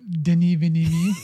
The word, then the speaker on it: Denis Villeneuve: Oh, Denis Villeneuve.